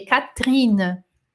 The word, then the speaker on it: Catherine: É, nós pronunciamos Catherine como se fosse assim, tipo, Catherine né? E por quê? Porque este E aqui, ele não tem acento, então seria Caterine, se tivesse um acento aqui, ó, Caterine, Caterina, né? E como não tem, a gente meio que disfarça ele, Catrina, tá?